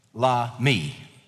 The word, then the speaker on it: Lahmi: Now, in our passage in 1 Chronicles chapter 20, the enemy's name, the giant's name was Lahmi, foodful. But I think the pronunciation of the name is interesting. Lahmi, the emphasis on me.